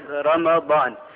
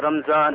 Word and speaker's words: Ramadan: Ramadan, the ulama mentioned that the word Ramadan, the correct pronunciation of Ramadan is Ramadan. بفتح الميمي, meaning with the fatha or the zabar or the nasab of meem, Ramadan. Not as some people say Ramadan or Ramzan or Ramjan, or we have different pronunciations.